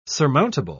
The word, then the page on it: surmountable: British English